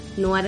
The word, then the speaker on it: noir: Y si los quieren buscar en Instagram, arroba petit noir, que en realidad se escribe noir. Noir es la pronunciación, aclaremos por las dudas para aquel que no sabe que es francés.